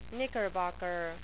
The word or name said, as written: knickerbocker